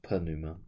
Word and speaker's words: pneuma: That's where the word pneumonia comes from, is the Greek word pneuma with a P, a silent P, which can mean breath, which is the connection to the medical problem, mm-hmm.